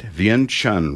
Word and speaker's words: Vientiane: Brother and Sister Oldham served in Laos, let me get this right, Vientiane recently.